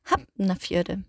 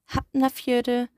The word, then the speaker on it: Hafnarfjörður: Dabei gibt es bei dem Ef aber eine Besonderheit, denn bei Wörtern wie Hafnarfjörður, ob, also immer wenn das F vor ein N oder einem L ist, beispielsweise bei Keplavik, dann wird das F wie ein B ausgesprochen. Das muss man immer im Hinterkopf behalten.